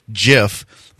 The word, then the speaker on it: GIF: yes, it's pronounced like the peanut butter. It's pronounced GIF, but it's been called a GIF for so long that people know what you're talking about more if you say that.